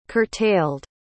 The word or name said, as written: curtailed